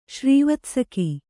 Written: ಶ್ರೀವತ್ಸಕಿ

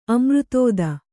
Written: ಅಮೃತೋದ

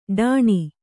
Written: ಢಾಣ

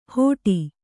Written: ಹೋಟಿ

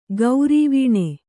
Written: ಗೌರೀವೀಣೆ

